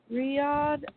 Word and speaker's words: Riyadh: FM3 has recently, recently expanded to produce worldwide events in Melbourne, Australia, oh gosh, Australia and Mm, Riyadh. Oh, I know that's not how you say it. And Saudi Arabia. – Actually, you got Riyadh right.